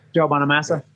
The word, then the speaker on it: Bonamassa: How do you pronounce his last name? – Joe Bonamassa. – Yeah, he's a badass guitarist.